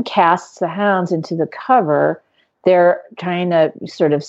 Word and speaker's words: covert: It's cover. COVERT. Cover. The T is silent.